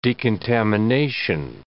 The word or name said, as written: decontamination